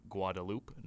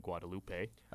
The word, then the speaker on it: Guadeloupe: Guadeloupe, not Guadalupe. We were pronouncing it Guadalupe because of the way a character in the movie Nacho Libre pronounces Guadalupe.